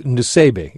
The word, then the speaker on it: Nusseibeh: The author is Sari, S-A-R-I, and that's a man. And I don't know how you pronounce the last name, N-U-S-S-E-I-B-E-H. – It's Nusebe. It's kind of like what it looks like.